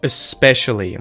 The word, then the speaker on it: especially: Okay, so especially, we use that vowel sound, the schwa at the start there, especially, and we just go straight into the S, especially, especially.